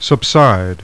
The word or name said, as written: subside